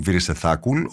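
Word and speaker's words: Βυρισεθάκουλ (วีระเศรษฐกุล): είναι με disclaimer βγαίνουν οι ταινίε του Νοέ, ο Απιτσατπόνγκ Βυρισεθάκουλ, ο Ταϊλανδό σκηνοθέτη που έχει πάρει και τον. – Έτσι προφέρεται. Γιατί κάποιοι το λένε Βερασεθακούν, κάποιοι Βερασεθακούλ, Γουερασεθακούλ.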